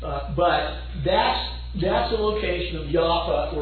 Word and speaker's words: Jaffa: They, in your Bible, it's spelled Joppa. It's pronounced Jaffa or Jaffa in their language.